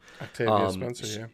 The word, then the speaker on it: Octavia Spencer: So, after actually looking into Octavia Spencer, mm-hmm. – right? I said that right, correct? – Octavia Spencer, yeah.